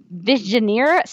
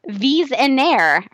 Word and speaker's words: Visionaire: She asks, stuck in the Phantom Zone, arguing Earth 38 legislative plot holes, or having to solve a Visioneer Square with no instructions on how to solve one. Oh, thank you. FYI, it's pronounced Visionaire, French, and it's very difficult to solve.